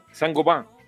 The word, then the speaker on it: Saint Gobain: No, eso es Saint Gobain.